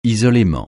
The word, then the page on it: isolément: francais